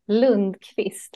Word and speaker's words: Lundqvist: My last name is Lundqvist, which is really hard to say.